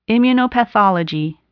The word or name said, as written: immunopathology